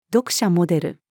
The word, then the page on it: 読者モデル: female